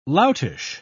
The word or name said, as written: loutish